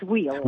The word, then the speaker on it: Weil: No, it's Weil.